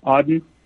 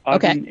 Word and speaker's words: Auden: Yeah, so everything that I'm seeing down here, I immediately report on X, and you can find me there as Cabello Auden. That's my last name and first name. – Okay, spell that. Spell that for us. – Cabello is C-A-B-E-L-L-O. Auden is A-U-D-E-N.